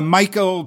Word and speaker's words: Mykel: Michael and Carly Allen, or is it Mikkel? – Michael, but it's spelled M-Y-K-E-L because right?